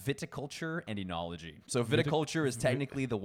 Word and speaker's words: oenology: Enology, but it's spelled O-E-N-O-L-O-G-Y. – But it's pronounced with an E? – Yeah, that O-E is like a enology.